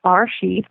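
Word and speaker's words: Bereshit: And the first word in the Torah is pronounced Barashit, which means beginning.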